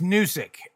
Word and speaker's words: Newsic: It's Newsic. – Like Gnocchi? – Yeah, I think Newsic is fine.